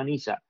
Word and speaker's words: Nyza: Encima de eso hicieron este furgoncito que se llama Niza, que se escribe Nyza,